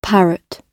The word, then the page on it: parrot: British English